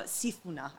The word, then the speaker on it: Sifuna: And the song has the lyrics, Sifuna and Njanje Ukaransa Dansa. And I just totally said that wrong. I'm so sorry for anyone. – It sounded really good. – And no, Sifuna. I always say it wrong.